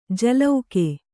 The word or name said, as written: ಜಲೌಕೆ